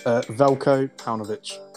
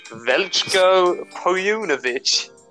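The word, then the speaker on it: Veljko Paunović: Velko Paunovic. – Yes. Exactly how I was going to say. Velko Paunovic.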